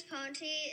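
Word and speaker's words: Ponti: Also, James Ponte, his last name is spelled weird. It's P-O-N-T-I.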